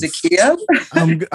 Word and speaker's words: Zakiya: It's when I feel like people break the syllables down in the incorrect space that it throws me. It's like, oh, it's Zakiya. I'm like, that's not what it says.